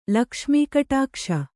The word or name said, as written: ಲಕ್ಷ್ಮೀಕಟಾಕ್ಷ